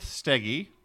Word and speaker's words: Stege: The first one is Elizabeth Steggy. I asked her how she pronounces her name, and I believe that is correct. – Steggy.